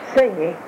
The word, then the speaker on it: Singy: Singy. – Sing, like singing a song? Mm-hmm. Singy.